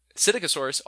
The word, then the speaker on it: Psittacosaurus: Psychotosaurus. – I've been bouncing back and forth. I go right? – Psittacosaurus. – Psittacosaurus. – Yeah. – We'll go with that. I like that one. Psittacosaurus also had counter shading.